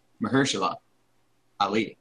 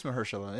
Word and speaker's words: Mahershala: You even said his name wrong. Get out of here. – Okay, well. – Get out of here. – Okay, say it correctly. – Mahershala Ali. – What? – Oh, there's an H in it? – Yeah. – Oh, you're right. Yeah, it is Mahershala, yeah.